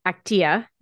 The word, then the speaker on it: Actia: And now you have you mentioned the startup that you're working with, Actia. Is this, did I say that correctly? – That's right.